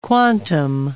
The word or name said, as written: quantum